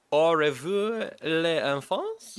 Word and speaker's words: Au revoir les enfants: The first option is called Au revoir les enfants? – Yep. – Yeah. – I believe that's how it's pronounced. – That sounded pretty good.